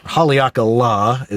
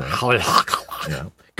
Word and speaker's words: Haleakala: And, you know, and we got to Waikiki and got to see the sunset from Mount Haleakala. Haleakala is the locals say. – Haleakala.